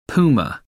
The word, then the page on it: puma: American English